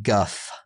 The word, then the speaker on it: Gough: And I thought Gough did a really I am reading now that it is pronounced Guff.